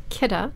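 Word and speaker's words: Kidde: It's Kidda. – Is that how you say it? – It's not Kitty.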